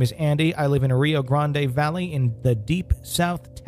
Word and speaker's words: Rio Grande: Up north, I think we say Grande. – We say Rio Grande. – Okay, Rio Grande.